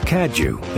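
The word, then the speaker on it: Cadieux: Cadju is pronounced with a J.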